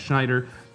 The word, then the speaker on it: Schneider: Schneider is spelled S-C-H-N-E-I-D-E-R.